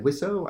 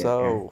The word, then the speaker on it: Wiseau: Wiseau? I don't know how to say it. How do you say Wiseau.